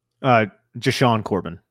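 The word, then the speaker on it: JaShaun Corbin: For me, it would actually be between Breida and Antonio Williams because I would be keeping Joshua Corbin or Joshuan Corbin. How do you pronounce his name? – Jashawn Corbin. – Jashawn. I don't know why I call him Joshuan. Jashawn Corbin.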